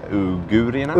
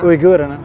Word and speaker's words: uigurerna: Men de, de, jag såg en talesman som stod och pratade om de här, eh, hur uttalas det nu då? U, eh, de här stackarna som hamnar i fångläger nu. Uigurierna? – Uigurerna.